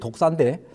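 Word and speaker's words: 독사인데 (δόξα): 여기 그 영광은 헬라어 원문의 독사입니다. 예, 발음이 그래요. 여기 저 독이 있는 독사가 뱀이 아니고 발음이 독사인데 이것은 구약에서 여호와의 임재를 나타내는 셰키나를 의미합니다.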